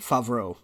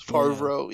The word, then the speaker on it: Favreau: John Favreau, or how do you pronounce his last name? – Favreau. – Since I'm terrible at names. Favreau, yeah.